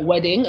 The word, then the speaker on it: Wedding: So it's spelt wedding as in like wedding in English, but it's pronounced Wedding because like the W in German is like V.